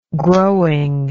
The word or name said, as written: growing